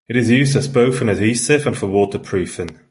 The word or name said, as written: waterproofing